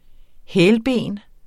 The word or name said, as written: hælben